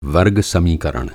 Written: समीकरण

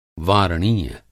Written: वारणीय

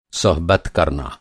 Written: सोहबत

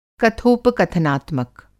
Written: कथोपकथनात्मक

